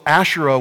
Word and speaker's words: Asherah: So Asherah was this female goddess that was also worshiped, pronounced Asherah.